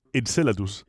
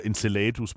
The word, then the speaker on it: Enceladus: Jeg er ikke sikker på, det er sådan, man udtaler Der er nogen, der siger enceladus, men det lyder bare så fordansket, at jeg siger enceladus.